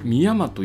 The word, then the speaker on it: 海山: それでですね、これから回る道の駅の下調べをしているんですけれども、今日下調べした道の駅は、三重県にある道の駅で、海と山、海山と書いて、三山と呼ぶそうです。